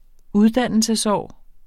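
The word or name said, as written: uddannelsesår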